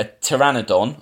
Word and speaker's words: pteranodon: So then, right, so the second version was by Peter Buckman, involving a pteranodon, am I saying that right? That's the P-T-E-R one. Yep, thank you.